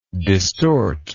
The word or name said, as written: distortive